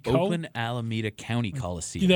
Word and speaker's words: Oakland Alameda County Coliseum: Oakland Alameda County Coliseum.